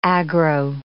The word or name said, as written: aggro